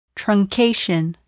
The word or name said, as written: truncation